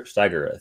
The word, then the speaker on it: Steiger: Steiger, I think, right? Is the pronunciation? – Yes.